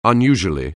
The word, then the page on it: unusually: anglais